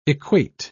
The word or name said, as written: equate